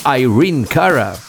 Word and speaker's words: Irene Cara: El Flash Dance de Irene Cara, que se pronuncia Irene Cara,